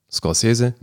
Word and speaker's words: Scorsese: Na, Scorsese, aber eben, also die Betonung ist dann ja, das ist ja italienisch und nicht spanisch.